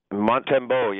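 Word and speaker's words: Montembeault: But second of all, you got to help me out on your new goalie, Sam Montembeau. Am I saying it right? Sam Montembeau? – Montembeau, yeah.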